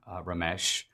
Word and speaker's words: Ramesh: At one time, he actually said to me something like, I want to be the next Dinesh D'Souza, Asian Indian Ramesh. His name even rhymes with Dinesh.